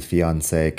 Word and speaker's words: fiancé: En fin, eso es el fiancé que sería el prometido o la prometida.